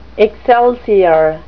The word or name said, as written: excelsior